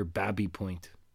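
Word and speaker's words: Baby Point: This neighborhood near here, Babby Point. Spelled baby, pronounced Babby.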